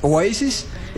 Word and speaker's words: Oasis: Hay gente que le dice Oasis. Pues lamento mucho, su pronunciación es Oasis.